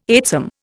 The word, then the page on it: eightsome: Indian